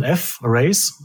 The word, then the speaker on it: F-Race: Und dann spricht man das halt so aus wie Fräse, also als Fräsen, aber eigentlich ist der Name F-Race.